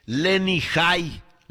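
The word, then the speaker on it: Lenny Jay: J-A-Y, Lenny High, Lenny High, para que me entienda, y él es el que hace This Is Michael, que va a estar con Jennifer Batten.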